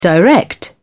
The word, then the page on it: direct: British English